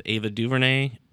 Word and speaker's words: Ava DuVernay: So like they're going to be doing a new Gods movie with Ava DuVernay. Uh, I hope I'm saying her name correctly. – Yeah, I think you're saying it right.